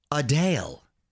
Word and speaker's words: Adele: And I had no idea, but apparently we've been pronouncing Adele's name incorrectly her entire career. She gives it a North London twist, Adele.